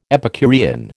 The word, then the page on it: Epicurean: American English